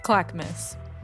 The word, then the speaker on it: Clackamas: A senior in high school living on occupied Clackamas land. Did I say that correctly? – Clackamas. – Clackamas.